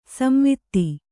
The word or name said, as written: ಸಂವಾದ